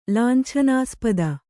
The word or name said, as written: ಲಾಂಛನಾಸ್ಪದ